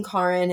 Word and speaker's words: Karin: But my third kind of nickname I kind of brought on myself because my name, being Karin, is kind of hard for some people to pronounce sometimes. They automatically want to go to Karen, Corinne, Carmen, or any version that's not my name.